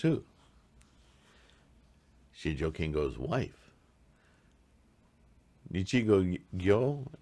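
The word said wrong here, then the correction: Nichigen-nyo: is that her name? Nichigo, where are you? Nichigenyo, sorry, said it wrong. Nichigenyo, Simon Nojo's wife, Shijo Kingo's wife.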